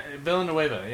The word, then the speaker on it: Villeneuve: It's Denis Villeneuve. – Villeneuve? – Villeneuve. Yeah, that's how you say it.